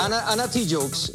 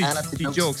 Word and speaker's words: Ana Tijoux: So, empezamos ese set con varias mujeres rockeras, empezando con Agorafobia, Triángulo de Amor Bizarro y también Ana Tijoux. I hope I said that right. What do you think, guys? – Ana? – Yeah. Ana Tijoux. – Ana Tijoux. Yeah. – Anything, I think it's Ana Tijoux.